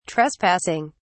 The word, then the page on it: trespassing: British English